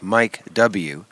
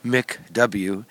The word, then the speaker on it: MICW: There's a company whose name is spelled M-I-C-W, like Mike W, Mick W,